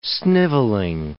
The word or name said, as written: snivelling